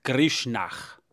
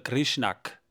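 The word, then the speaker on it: Grishnákh: Der heißt übrigens Grishnach oder Grishnak wird er geschrieben mit KH am Ende, also Grisch mit SCH und NAKH, wobei man könnte, man, es, es könnte ausgesprochen werden als Grishnach. Ja? Also mit diesem kehligen und aus diesem Streit geht hervor, dass Saruman verlangt, die Hobbits lebendig und unversehrt nach Isengard zu bringen.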